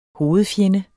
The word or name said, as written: hovedfjende